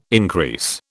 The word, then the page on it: increase: British English